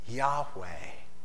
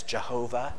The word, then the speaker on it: Yahweh: It's likely pronounced as Yahweh, if you recognize that. That this is the name of God, Yahweh. Some people historically have pronounced it as Jehovah. That's probably not a correct pronunciation.